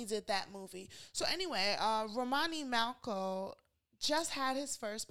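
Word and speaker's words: Romany: Is that how you say his name? Romani? – Yes, Romani Malco.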